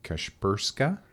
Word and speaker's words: Kashperska: Her name is Halyna, and I'm just going to try to pronounce the last name, Kashperska? – Correct.